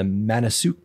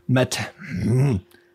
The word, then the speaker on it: Matanuska: Manasuk. I practice how to say this. I practice, I swear. Matanuska.